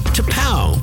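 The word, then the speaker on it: T'Pau: T-apostrophe-P-A-U, pronounced T-Pow.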